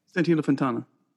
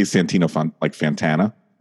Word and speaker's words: Santino Fontana: Santino Fontana. – Oh, I love how you say it, because I'm horrible. I say Santino Fantana or Santino Fontana.